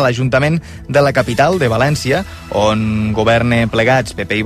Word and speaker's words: València: La versió en català o valencià s'haurà d'escriure amb accent tancat a la E. Així doncs, s'hauria de pronunciar València.